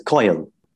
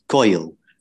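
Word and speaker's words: koel: Now, I'm of Indian descent and my mum speaks with a slight Indian accent. So she says Kaul. And those two syllables, Kaul, really reflect the bird's call because that's what it's named after, its call.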